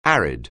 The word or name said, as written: arid